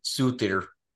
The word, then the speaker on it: Surtur: Asgardian Secret, which to me smelled like licorice, and Surtur's Fury, or Suthir, as it's more properly pronounced.